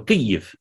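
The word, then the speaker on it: Kyiv: There are people saying Kiev, but it's actually in Ukrainian, it's Kiev. – Kiev. – You're nearly there. – Kiev. – Yeah. It's Kiev. The first syllable is Ki, and the second is Yiv. So Kiev.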